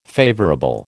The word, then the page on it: favourable: American English